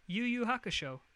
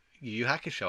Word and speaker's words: Yu Yu Hakusho: How do you say it? – Yu Yu Hakusho. – Yu Yu Hakusho, I thought it was.